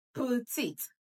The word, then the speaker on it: petite: Whenever you come across this word, P-E-T-I-T-E, it's pronounced as putit, putit, not petit. So you're not going to sound it as E. It's putit. It has two syllables and the stress is on the second syllable.